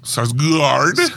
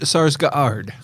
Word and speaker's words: Sarsgaard: Sarsgaard? – It's pronounced Sarsgaard.